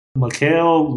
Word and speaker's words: Mikael: And the author of this book is Michael London. I know it doesn't say Michael. It's spelled M-I-K-A-E-L.